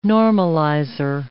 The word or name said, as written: normalizer